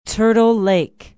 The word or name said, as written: turtle lake